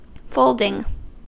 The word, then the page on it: folding: American English